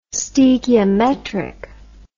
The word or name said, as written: stoechiometric